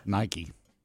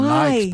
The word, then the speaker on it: Nike: You know, want to know the simple one that I mispronounced for years? – Yes. – Nike. – Did you call them Nikes? – Yeah. – Why? – Really? Yeah. I called them Nikes probably into my 20s.